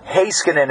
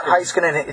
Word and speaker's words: Heiskanen: Uh, Shani writes in uh, asking about the pronunciation for Miro Heiskanen. It is not Heiskanen, it's Heiskanen.